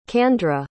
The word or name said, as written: candra